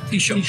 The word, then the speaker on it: Taoiseach: It's t shot.